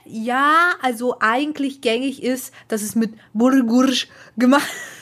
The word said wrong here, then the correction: Bulgur: Sorry, weil ich falsch ausgesprochen habe. Dass es eigentlich mit Bulgur gemacht wird.